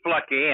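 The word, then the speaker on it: Fluckey: Well, Lucky Flucky, and that's how I always remembered how to pronounce his name.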